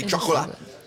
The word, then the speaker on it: chocolat: That's how you say it in French. – You just... – I mean, in French, you say chocolat.